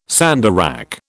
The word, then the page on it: sandarac: British English